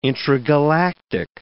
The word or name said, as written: intragalactic